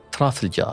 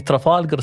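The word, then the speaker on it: Trafalgar: ترافلجار الجار الأصدقاء الذين سيستفزهم نطقي للكلمة وسيسارعون لتصحيحها ويقولون أن التسمية الصحيحة هي ترافالجر سكوير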